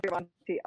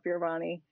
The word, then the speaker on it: Fioravanti: Um, and then uh, Kayla Fioravanti um, on, I think it's Kayla Fioravanti Ology is my business page, um, personal business page. And then I'm also on Instagram with that, Kayla Fioravanti,